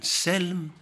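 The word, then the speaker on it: سِلْم: Selm or Salm, both of them have been pronounced in the Quran.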